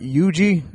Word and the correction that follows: Usagi Yojimbo: How do you pronounce this? Yugi. – Usagi Yojimbo.